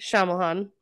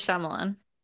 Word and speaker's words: Shyamalan: Night Shyamalan. How do you say it? Say it again. – M. Night Shyamalan.